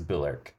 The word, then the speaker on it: Bulwark: So if you see W-A-R-K, that is always Urk. – Urk. – You don't pronounce Southern. That W and it's Urk. So like another one would be, have you ever seen Bulwark? – I don't think so.